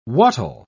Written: what'll

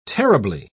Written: terribly

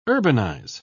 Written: urbanize

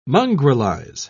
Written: mongrelize

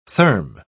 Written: therm

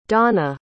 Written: dawna

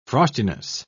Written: frostiness

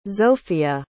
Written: zofia

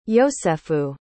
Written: yosefu

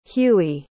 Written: hughie

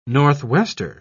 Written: northwester

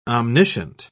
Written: omniscient

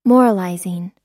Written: moralizing

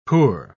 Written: poor

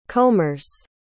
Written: combers

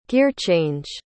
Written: gearchange

